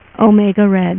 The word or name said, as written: Omega Red